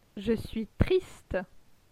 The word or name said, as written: Je suis triste